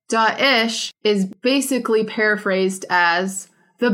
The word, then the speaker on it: Daesh: So a lot of people are saying Daesh, Dash. The correct pronunciation is Daesh.